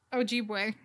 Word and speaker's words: Ojibwe: Ojibwe. – Ojibwe.